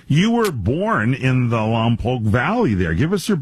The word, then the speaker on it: Lompoc: Lompoc. – Lompoc. L-O-M is your lom, P-O-C, say poke, and you got it right.